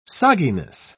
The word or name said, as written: sogginess